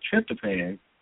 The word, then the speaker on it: tryptophan: The one that I have tried to explain is the tryptophan. And I say, even saying it right, tryptophan or tryptophan? – Tryptophan, yeah.